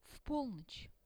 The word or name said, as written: v polnoch